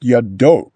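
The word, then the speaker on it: yado (ידו): Now, yud, dalet, vav can be pronounced yado, right? It doesn't necessarily say yadav.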